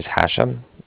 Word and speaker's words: Hashem: My name is Hashem. – Can you spell that for me? – Absolutely. It's H-A-S-H-E-M, as in mother at the end.